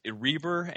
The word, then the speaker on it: Reber: You know, that's, yes, Reber, and it's funny because people say Reiber. It should be Reiber, I believe, but, you know, of course, Americanizing a German name.